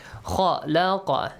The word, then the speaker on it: خلق: Kha laqa. So kha has a heavy sound. Kha laqa. Kha also has a heavy sound. Lam has a light sound.